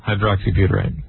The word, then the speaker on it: hydroxybutyrate: How do you say it, though? I screwed that up. – Gamma. – And it's a drug. – Gamma? – Hydroxybutyrate.